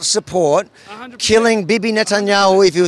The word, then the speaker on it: Netanyahu: Benjamin Netanyahu. – Netanyahu.